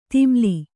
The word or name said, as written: ಧೂಮಲ